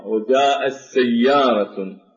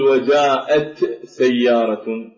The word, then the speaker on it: وجاءت سيارة: في قوله تعالى وجاءت سيارة، فهنا التاء لا ينطق بها، وجاءت سيارة، لا نقول وجاءت سيارة، بالقراءة القرآنية نقول وجاءت سيارة، فننتقل من الهمزة إلى السين ونشدد السين وننطق بها رأسا.